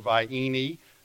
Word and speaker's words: Vienna: Ronnie talked about Vienna sausage, spelled like Vienna, V-I-E-N-N-A, but pronounced in our area Vienna or Vienne, and potted meat at J.C.